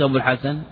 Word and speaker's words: أبو الحسن: مر معنا قال أبو الحسن، كيف تكتب أبو الحسن؟ بالواو لكن ما تنطق بها.